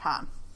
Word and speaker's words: pain: Is it pronounced Pain and not Pan or Pom? – I don't really pronounce the N, but it is like Pom.